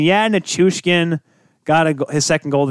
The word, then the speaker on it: Nichushkin: Oh, Nichushkin.